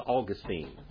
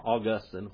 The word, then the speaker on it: Augustine: One of the first men to write of legislated morality was Augustine, or Augustine, whatever you want to call him. Augustine is how you pronounce his name, but now these hotshots, Augustine.